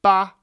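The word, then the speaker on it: Ba (疤): So what she's probably referring to is Harry's Shang Ba on his forehead, and the Ba, why is it pronounced B-A? Well, because it has underneath the illness component, the character for Ba, which means crust, and that's the phonetic component of this character.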